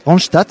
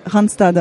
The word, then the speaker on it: Randstad: Alors vous, c'est l'entreprise Randstad. Je prononce bien Randstad. – On va dire Randstad, d'accord.